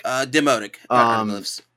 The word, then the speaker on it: demotic: But yes, the Rosetta Stone, I believe the origination of that, too, was ancient Egyptian hieroglyphic and demotic, I think is how you pronounce it. Demotic. – Demotic. – Demotic, yeah, which which, you know, respectively, of course, this is all falls underneath ancient Greek.